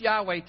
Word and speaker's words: Yahweh: It's pronounced Yahweh today. But in actually,